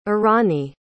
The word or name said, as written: irani